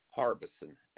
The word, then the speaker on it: Harbeson: Jerry Harbison, H-A-R-B-E-S-O-N. – And is Jerry spelled J-E-R-R-Y? – That's correct.